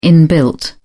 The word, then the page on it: inbuilt: British English